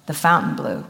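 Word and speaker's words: Fontainebleau: And I went by myself, and I went and I stayed in the Fountain Blue, which is how they say it.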